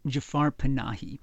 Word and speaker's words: Jafar Panahi: Yes, his name is Jafar Panahi, and he is probably the person who's been the most interesting Iranian director over the last 10 or 12 years.